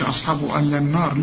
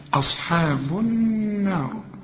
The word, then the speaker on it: أصحاب النار: اللام دي شمسية بتضغم في النون، ما تقولش أصحابه ألا النار، لا، أصحاب النار.